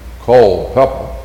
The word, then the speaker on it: Culpeper: Lee Davies was from a little place called, and I'm going to say it right, Culpeper. He came from Culpeper.